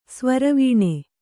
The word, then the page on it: ಸ್ವರವೀಣೆ: Kannada